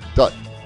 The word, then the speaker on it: Dutt: Dutt.